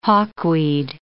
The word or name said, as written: hawkweed